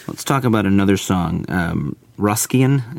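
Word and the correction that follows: Roscian: is that how you say it? – Rosian. – Rosian.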